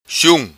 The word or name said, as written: Sium